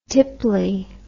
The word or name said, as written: tipply